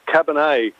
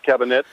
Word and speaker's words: Cabernet: I most certainly have, and I believe it's pronounced Cabernet, not Cabinet, whatever you said.